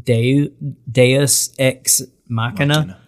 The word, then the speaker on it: Deus ex machina: Deus ex machina.